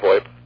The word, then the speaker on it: VoIP: Yeah, originally, you know, everybody was calling it voice over IP, but it's VoIP. I call it VoIP.